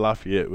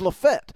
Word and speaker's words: Lafayette: You said Lafayette, but it's like Lafayette. – It's Lafette. Yeah.